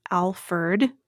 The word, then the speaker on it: Alferd: even though he's generally known as as Alferd, like with the, ERD. – Yeah, ERD.